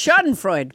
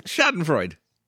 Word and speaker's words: Schadenfreude: How did you just pronounce it? – I don't know. Scheherazade? What was it? – No, it's Schadenfreude. – Schadenfreude.